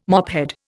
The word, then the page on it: mophead: Indian